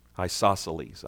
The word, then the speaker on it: isosceles: Isosceles. – Isosceles.